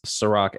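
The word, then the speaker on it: Serak: It's Ciroc, S-E-R-A-K. I know, unfortunately, not spelled like the liquor, but I promise you, I taste way better.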